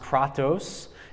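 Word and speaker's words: kratos (κράτος): This word in Greek is pronounced kratos.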